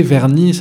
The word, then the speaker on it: Vergnies: Alors pour vous qui nous écoutez, Vernis, ça s'écrit V-E-R-G-N-I-E-S.